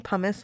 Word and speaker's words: pomace: I'm saying pumice like pumice stone, but it's spelled P-O-M-A-C-E.